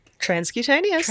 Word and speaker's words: transcutaneous: Transcutaneous.